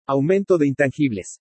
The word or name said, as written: AUMENTO DE INTANGIBLES